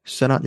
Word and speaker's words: Sennott: Definitely has the same star, which is Rachel Sennott. Sennott? I don't know how to say her name. – Sennott, yeah.